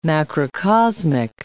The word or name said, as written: macrocosmic